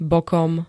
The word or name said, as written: bokom